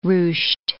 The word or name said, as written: ruched